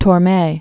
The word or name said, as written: Torme